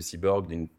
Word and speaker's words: C-Borg: Cyborg, j'essaye de réinventer un peu le terme de cyborg avec une connotation plus positive, c'est C-Borg pour Creative Borg, mais prononcer cyborg.